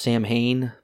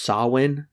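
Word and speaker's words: Samhain: And then we just get a massive exposition dump where he talks about the history of I, I I always thought it was pronounced Sam Hain because that's phonetically how it's spelled. Yeah. And that's how Donald Pleasence says it in the last movie. If you'll remember, Michael Myers writes it's technically pronounced Sawin. Uh, it's Celtic.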